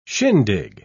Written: shindig

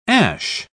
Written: ash

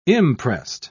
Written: imprest

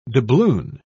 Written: doubloon

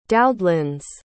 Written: doudlens

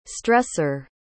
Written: stresser

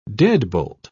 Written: deadbolt

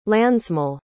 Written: landsmål

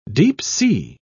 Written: deep-sea

